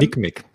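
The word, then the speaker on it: NCMEC: Unter anderem diese Neckmeck, äh, wie hast du das ausgesprochen? – Nick, Nickmeck, ja. Nickmeck. Nick ja.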